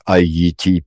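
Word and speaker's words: Ayiti: Вот на креольском прямо так Айити и пишется.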